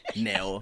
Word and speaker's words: Nao: Then you're like, N-E-O. Then they're like, "Oh, so like Neo." And I'm like, "That's definitely not what I said. I definitely said Nao."